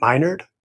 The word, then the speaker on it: Minard: My name is Dr. Matt Minard, pronounced my nerd, rightfully so, and I am honored to be your host.